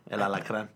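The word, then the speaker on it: El Alacrán: That's how you say it? – El Alacran.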